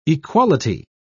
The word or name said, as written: equality